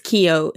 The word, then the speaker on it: Keough: Sister Kathy taught English and drama while at Keogh High School, which when I was watching the documentary, I thought they were all pronouncing Keogh wrong. Oh. Keogh, the last name. – Yeah. – But no, it's Keogh. It's like K-E-O-U-G-H. But I thought it was just Americans butchering an Irish name.